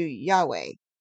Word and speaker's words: Yahweh: The actual pronunciation of Yod Heh Vav Heh is closer to Yahweh, but out of reverence, many Jews say Adonai instead.